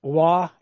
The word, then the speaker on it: Waugh: There's Billy Waugh.